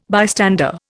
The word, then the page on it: bystander: Indian